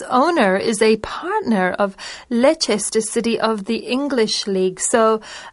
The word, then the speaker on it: Leicester: You wouldn't think Leicester when you read that, but Yeah, it's pronounced like L-E-S-T-E-R, Leicester City.